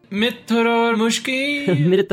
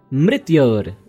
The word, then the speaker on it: Mrityor: Mitra-ar-mushki. – No, it is Mrityor-mukshiya. Mrityor. Yes. Mrityor.